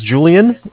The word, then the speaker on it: Julian: Okay, my name is Julian. It's J-U-L-I-A-N. – I-A-N, Yeah, and I'm calling with Bank of Cardiff. It's C-A-R, D as in David, I-F-F, like Frank.